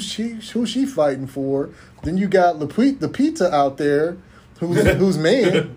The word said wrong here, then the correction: Lupita: Did I say it wrong? Lupita, yeah. Yeah, her, yeah, her man dead.